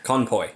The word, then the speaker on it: conpoy: That's a canopy. – Conpoy. – I'm saying canopy. I'm saying it wrong, but yeah.